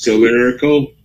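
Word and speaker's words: Talarico: Isn't that James Tallarico? – Tallarico, yes.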